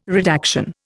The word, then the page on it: redaction: Indian